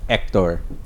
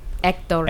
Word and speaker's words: Hector: How would you say Hector in Spanish? – Hector. – Oh. – You don't, okay. The H is silent.